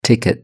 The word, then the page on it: ticket: British English